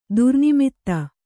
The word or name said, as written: ದುರ್ನಿಮಿತ್ತ